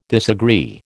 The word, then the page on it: disagree: American English